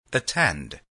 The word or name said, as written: attend